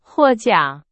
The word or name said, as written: 获奖